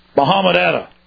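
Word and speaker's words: Mohamed Atta: Mohammed Atta.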